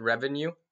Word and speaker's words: Revonew: Yeah, so the name for our app is called Revenue. – Okay. – It's spelled R-E-V-O-N-E-W.